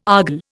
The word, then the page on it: argol: Indian